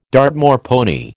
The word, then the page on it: Dartmoor pony: American English